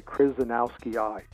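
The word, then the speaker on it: krasnowskii: It really, don't look at the way it's written, just say Krasnowski-I.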